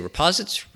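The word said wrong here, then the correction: riposte: How do you say that word? – Reposts. – Reposts, thank you.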